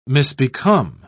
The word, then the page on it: misbecome: British English